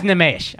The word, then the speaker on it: Nimesh: I flub on Indian names because you guys put the emphasis on different syllables. – Yeah. – time. – Yeah. – Like Nimesh. – He's a fucking idiot. Nimesh Patel is my guy, but his name is Nimesh, all right? – Nimesh?